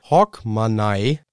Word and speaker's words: Hogmanay: Ja, da Neujahr einer der wichtigsten schottischen Feiertage ist, kann dieser hier, und ich kann es nicht richtig aussprechen, Hogmanay, H-O-G-M-A-N-A-Y, Hogmanay.